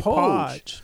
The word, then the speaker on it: Poage: P-O-A-G-E. P-O-A-G-E. I want to say Probably Podge. – You want to say Podge? I say Podge. But P-O-A-G-E was the Poge. First black man. – Is it Poge? – Poge. P-O-A-G-E.